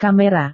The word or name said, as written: kamera